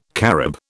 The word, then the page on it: carob: British English